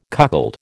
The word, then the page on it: cuckold: American English